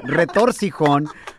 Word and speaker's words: retorcijón: She said it wrong, Eddie. Don't start saying that. Retorcijón.